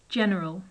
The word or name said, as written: general